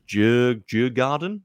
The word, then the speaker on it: Djurgården: So Ghent have got Molder, Shamrock Rovers, and I'm really sorry, I cannot pronounce the name of this last team, Djurgården, from Sweden.